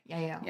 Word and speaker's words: Yael: I don't know if I've said her name right. It's spelled Y-A-E-L. Yeah, Yael.